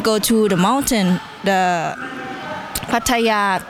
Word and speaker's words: Pattaya: Pattaya.